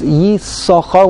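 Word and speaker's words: Yissachar: There are two sins. Yisochar. We only pronounce the first sin. We do not pronounce second.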